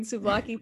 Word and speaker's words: souvlaki: Rice and beans, curry chicken, ratatouille, steak, seared tuna, or souvlaki? Souvlaki? Am I saying that right? – Yeah. – S-O-U-V-L-A-K-I.